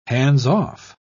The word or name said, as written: hands-off